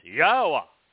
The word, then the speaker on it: Yahweh: So Yod Hey Wah Hey is Yahweh.